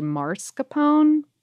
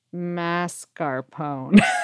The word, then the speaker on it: mascarpone: I would also like to gently offer a tiny bit of help to Lauren about the pronunciation of mascarpone. – Yes. You're doing this live. – The first half of the word throws a lot of people, but it should rhyme with NASCAR.